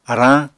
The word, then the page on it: arañ: Apatani